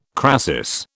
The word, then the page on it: crasis: British English